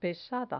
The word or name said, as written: pesada